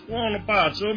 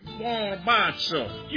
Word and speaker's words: buonapazzo: Well, what does Bona Pizza mean, Dad? – It's buonapazzo. It means crazy good. You're not saying it right. Buonapazzo. You try it.